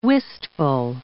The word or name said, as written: wistful